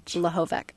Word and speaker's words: Lehovec: L-E-H-O-V-E-C. – And you pronounce it? – Lehovic.